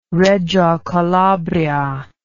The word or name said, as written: reggio calabria